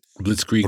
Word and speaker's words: Blitzkrieg: And of course, Briskling, I can't pronounce it. – Blitzkrieg Briskling Bop.